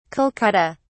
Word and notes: kolkata: British English

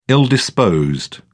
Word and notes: ill-disposed: British English

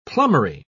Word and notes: plumbery: British English

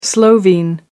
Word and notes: slovene: American English

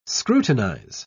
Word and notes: scrutinise: British English

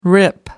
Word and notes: rip: American English

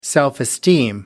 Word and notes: self-esteem: American English

